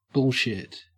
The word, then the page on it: bullshit: Australian English